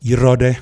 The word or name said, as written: YIrawde